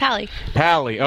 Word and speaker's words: Pally: And last week we had Adam, I always say Paley, but it's Polly. How do we say it? – Pally.